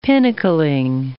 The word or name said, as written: pinnacling